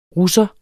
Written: russer

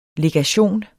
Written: legation

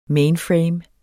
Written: mainframe

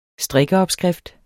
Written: strikkeopskrift

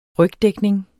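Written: rygdækning

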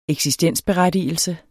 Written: eksistensberettigelse

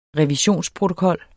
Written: revisionsprotokol

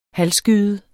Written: halvskyet

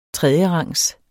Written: tredjerangs

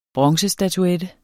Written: bronzestatuette

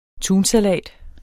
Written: tunsalat

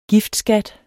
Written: giftskat

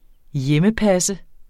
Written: hjemmepasse

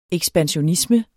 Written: ekspansionisme

